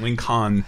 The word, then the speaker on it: Lincoln: He says L-I-N-C-O-N. – He doesn't leave out the silent L. Mm-hmm. Lincoln.